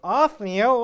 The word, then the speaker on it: Othniel: Starts off with Othniel. And Othniel is a man who pursues doing what is right. My wife remembers listening to a sermon many, many years ago, and she remembers the guy saying, "Othniel was faithful."